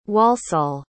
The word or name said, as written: walsall